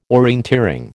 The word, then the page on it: orienteering: American English